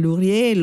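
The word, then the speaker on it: Lourié: E in questo modo arriviamo proprio all'ultimo autore, all'ultimo brano in programma, l'autore Arthur Vincent Lurier. Lo pronuncio alla francese, appunto, in ragione anche del suo trasferimento in Francia negli anni 20,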